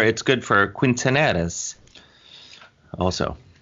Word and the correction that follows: quinceañera: Am I saying that right? – I think it's quinceañera.